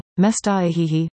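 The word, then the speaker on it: Mestaa'ehehe: Governor Jared Polis is getting pushback after saying the new recommended name for a mountain in Clear Creek County, Mestahehe, pronounced Mestahe, is too hard to pronounce as written.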